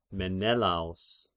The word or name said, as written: Menelaos